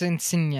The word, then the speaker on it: Insigne: But that wasn't, yeah, it's Insigne, I'm pretty sure.